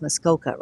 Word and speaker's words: Muskoka: Muskoa? I don't know if I'm saying that right. Yeah, it is Muskoka, right? So it was just written wrong there, but and they are an apostolic hub in Ontario, and they had to make choices during this last episode.